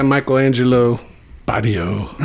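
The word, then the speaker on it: Michelangelo Batio: So this guy, Michelangelo Baddio. Yeah. I don't even know if I'm saying that right. – I think that's right.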